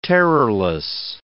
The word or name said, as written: terrorless